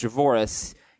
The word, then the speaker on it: Javorius: Yeah, Allen, Javorius Allen, I think it's actually pronounced Javoris.